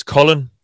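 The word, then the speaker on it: Khalen: I dag har de så øh, i hvert fald delvis erstattet dem med Nathan Shepard for Jets og øh, Ja, det skal åbenbart udtales Colin, Callen Saunders øhm, fra Chiefs. Kalen Saunders, vil jeg nok sige. Men jeg så en eller anden skrive, ja. at det udtales ligesom Colin.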